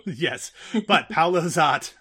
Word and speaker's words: Szot: I don't remember when it was or who said it, but someone said something like, pro tip, Paolo S-Z-O-T rhymes with hot. So I don't know if that was as much a pronunciation tip or just a fact about the attractiveness of Paolo Zott.